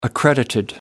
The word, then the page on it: accreditation: American English